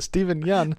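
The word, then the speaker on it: Steven Yeun: Stephen Yeun.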